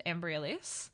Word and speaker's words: Embryolisse: I can pronounce the brand, Embryolisse.